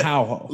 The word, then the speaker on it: Araujo: We had Andrea Lee versus Araujo. I'm just gonna say Araujo. I'm butchering her name. – But I think you can say Araujo. – But the R is the H.